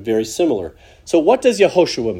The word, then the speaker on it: Yehoshua: So the name which we pronounce as Jesus is the English translation of the Hebrew name Yehoshua. Yehoshua.